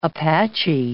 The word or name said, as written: Apache